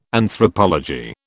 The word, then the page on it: Anthropology: British English